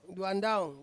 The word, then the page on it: duandaung: Ansus